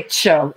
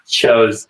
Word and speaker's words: TCHO: Just say CHO. It's like the first syllable. CHO rolls off the mouth a little easier.